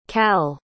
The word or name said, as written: cal